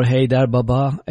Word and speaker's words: Haydar Baba: And again, it's "Hail to Haydar Baba," or "Haydar Baba" as it is pronounced here.